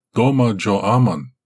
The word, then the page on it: doma jo aman: Galindri